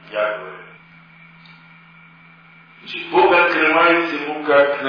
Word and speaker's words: Яхве: Получилось слово Иегова. Вот впоследствии в 19 веке ученые, обратившись к отцам церкви, поняли, что это ошибочное чтение, и в настоящее время условно имя читается как Яхве или Яхве.